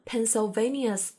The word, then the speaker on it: Pennsylvania: 好，接下来一小节的第一句出现的三个州是 Oklahoma、 Oregon，还有 Pennsylvania。 – Oklahoma, Oregon, Pennsylvania's Liberty Bell. – 前两个州都没什么难的，直接按照拼写去读就好了。第三个州的名字稍微有点长，它的前半部分的发音啊，跟我们那个铅笔的单词 pencil。是一模一样的，Pennsylvania。那歌词里唱的 Pennsylvania's Liberty Bell 指的是什么呢？Oklahoma, Oregon, Pennsylvania's Liberty Bell。